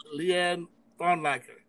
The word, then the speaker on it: Leigh Ann Flounlacker: My guest today is Leigh Ann Flounlacker.